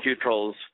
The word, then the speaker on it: Cutrell: Brandon Cutrell's, Cutrell, rhymes with neutral.